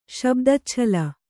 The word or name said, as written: ಶಬ್ದಚ್ಛಲ